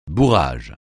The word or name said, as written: bourrage